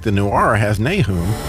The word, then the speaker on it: Nahum: Nahum is the Hebrew pronunciation. Nahum is more Americanized. But when in America, Nahum it is.